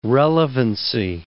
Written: relevancy

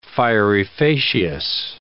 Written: fieri facias

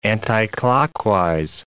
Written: anticlockwise

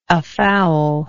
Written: afoul

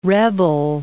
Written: rebel

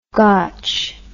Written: gotch

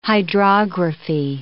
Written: hydrography

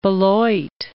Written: Beloit

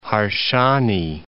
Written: Harsanyi

